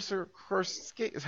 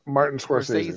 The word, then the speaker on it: Scorsese: I mean, yes, I've seen the previews for it, but it's a Martin Scorsese. How do you say his last name? – Martin Scorsese.